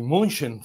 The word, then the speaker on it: München: Pero la película que les quiero recomendar el día de hoy es bien interesante y se titula, así se titula, eh, Munich, si lo pronunciamos en inglés, Múnich, si lo pronunciamos en español, y München, si lo pronunciamos en alemán.